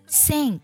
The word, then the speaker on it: sink: 第一个单词 sink，这个单词呢是下沉的意思，拼作 S-I-N-K sink。这个单词我们在读的时候呢，字母 S 读作摩擦音，你在发音的时候呢，感觉气流呢从你的上齿和下齿中间的齿缝呢给呲出来那种感觉。